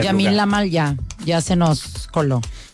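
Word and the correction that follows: Lamine Yamal: Lamin Yamal.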